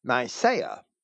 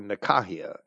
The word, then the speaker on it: Nicaea: that of the 318 bishops held at Nicaea, or Nicaea, as I would pronounce that from the Greek,